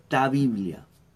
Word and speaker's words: ta Biblia: La palabra de Dios, Biblia, Biblia, viene de la palabra griega koiné, del griego koiné, que se dice ta Biblia. Ta Biblia se escribe, se pronuncia y se dice.